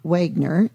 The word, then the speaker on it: Wieger: Robert Wagner, and that's spelled W-I-E-G-E-R, design the 150-room complex.